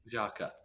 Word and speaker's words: Xhaka: Xhaka, there it is.